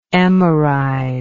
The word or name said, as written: emerize